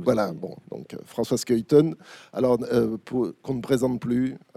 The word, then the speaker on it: Schuiten: Alors, la présentation de, alors pour vous c'est de droite à gauche, donc François Skuyten, j'ai la bonne prononciation? Voilà. – Oui, ça va. – Non, parce que non. La majorité des gens présents dans cette salle, très probablement, vous appellent Schuiten, moi-même, bon.